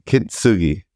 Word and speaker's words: Kintsugi: K-I-N-T-S-U-G-I. Kintsugi.